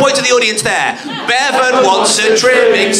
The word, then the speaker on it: Bevan: So what's the bit of his name that rhymes with seven? – Nye Bevans trimming. – No, it's not that. It's kind of a poor Bevan. – Yes, it is in fact.